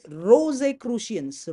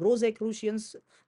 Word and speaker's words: Rosicrucians: Rosicrucians. Rosicrucians. Let me spell that for you. Rosicrucians is spelled as R-O-S-I.